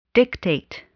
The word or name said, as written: dictate